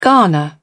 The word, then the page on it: garner: British English